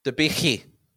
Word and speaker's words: Debicki: Like Elizabeth, is it Debicki or Debicki? How do you pronounce her name? – Debicki.